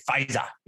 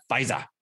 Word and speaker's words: Pfizer: After the federal government late Tuesday authorized Pfizer's coronavirus vaccine, you got to say Pfizer, you have to say it like that, Pfizer, coronavirus vaccine for emergency use for kids 5 to 11, California implemented what state officials deemed a robust vaccination program.